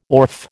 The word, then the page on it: orfe: American English